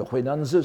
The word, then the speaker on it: Huainan: Maître Huainan, le Huainanzi, c'est-à-dire le grand écrivain taoïste des environs du début de l'ère chrétienne en Chine, le Enanji en japonais, qu'on prononce ici Huainan, n'est-ce pas ?